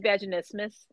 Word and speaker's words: vaginismus: And I guess the follow-up question of that was, and tell me if I'm saying this wrong, what is vaginismus? I don't know if I said that correctly. – You did.